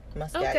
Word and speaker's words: Muscat: Muscat.